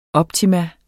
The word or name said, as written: OPTIMUMMETS